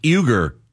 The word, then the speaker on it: Uygur: One of the hosts there, Cenk Uygur, am I saying that right? I know it's Cenk. – I think so. – Cenk, C-E-N-K, Uygur, U-Y-G-U-R.